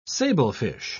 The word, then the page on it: sablefish: British English